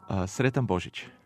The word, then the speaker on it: Sretan Božić: Sretan Božić. – Sretan Božić.